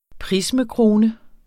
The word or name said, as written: prismekrone